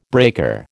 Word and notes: breaker: American English

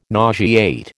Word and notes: nauseate: American English

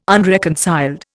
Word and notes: unreconciled: Indian

